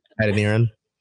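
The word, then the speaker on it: Adeniran: So on the 15th, the club exercised the 2023 contract option of Sam Edinera. Can you say that for me? – Edineran. – There we go. I didn't want to butcher it.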